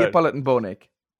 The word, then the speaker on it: Neapolitan bone ache: There's no way you're pronouncing that right. Neapolitan No, that's absolutely right. It's actually, it's not bony achy or anything like that. Neapolitan bone ache.